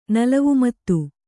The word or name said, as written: ನಲವುಮತ್ತು